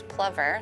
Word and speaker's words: plover: I think the biologists are saying the correct pronunciation is plover.